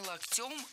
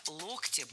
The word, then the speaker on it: локтем: Задел не локтем, а локтем. Слово «локоть» склоняется по следующей схеме. В единственном числе ударным остается корень слова «локтя», «локтю», «локте», «локтем». А во множественном ударение переносится на окончание «локтей», «локтями», «локтях».